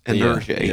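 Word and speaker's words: Nergy: I think it's French. It's pronounced Energy. – Yeah, that's N-E-R-G-Y.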